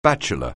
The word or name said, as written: bachelor